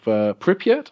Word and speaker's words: Pripyat: Pripyat.